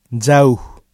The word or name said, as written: jauh